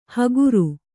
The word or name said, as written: ಹಗುರು